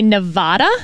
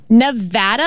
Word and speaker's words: Nevada: because a lot of politicians would say Nevada. – Wrong. – And that is wrong. – Wrong. It is Nevada.